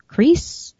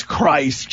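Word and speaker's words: Kreis: Is it Kreese? – It's Christ. Jason Christ. Yeah.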